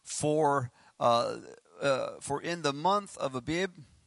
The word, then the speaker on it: Abib: Abib. It's kind of hard to pronounce. A-B-I-B.